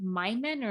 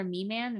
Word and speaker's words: Miman: There's these little guys you can create called Mimen or Miman. It's spelled M-I-M-A-N.